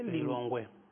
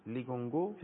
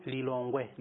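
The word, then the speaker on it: Lilongwe: لیلونگوه. – لیگونگو؟ – لیلونگوه.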